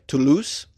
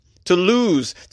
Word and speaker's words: Toulouse: Did I say it right? Toulouse? Toulouse. To lose your cash.